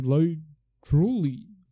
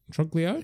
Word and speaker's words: Truglio: Uh, but uh, but Mark, who is the other lawyer there, uh, is played by Joe Lodrulio. Truglio.